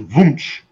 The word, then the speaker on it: Wunsch: In German, it's Wunsch. – Wunsch. – The W sounds like a V.